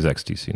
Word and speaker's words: XTC: The band's XTC. – Mm-hmm. I heard you talking about that. – And we are, it is XTC, not ecstasy.